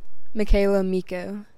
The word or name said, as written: Michaela Mikko